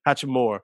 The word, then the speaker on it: Hachimura: Hachimura.